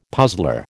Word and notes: puzzler: American English

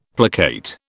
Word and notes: placate: British English

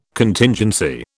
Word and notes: Contingency: British English

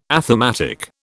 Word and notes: athematic: British English

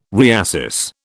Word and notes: reassess: British English

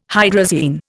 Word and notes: hydrazine: Indian

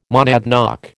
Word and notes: monadnock: American English

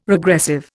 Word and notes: regressive: Indian

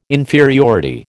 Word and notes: inferiority: American English

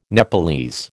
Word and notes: Nepalese: American English

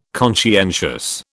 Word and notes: conscientious: British English

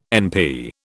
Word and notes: NP: British English

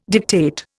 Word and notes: dictate: Indian

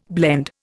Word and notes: blend: Indian

